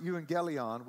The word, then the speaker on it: euangelion: EU, the U pronunciation of euangelion really comes from that part of the word which you'd almost hear when you hear the word eulogy.